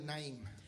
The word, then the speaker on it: Naim: Tenemos la viuda del hijo de Naim. – We have the widow of the son of, how do you say that in English? – Naim. Amen. Right?